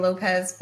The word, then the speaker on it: Lopez: It's Jada Lopez for everything.